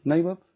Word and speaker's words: Naboth: Do I say that right in English? Naboth? Naboth is the name?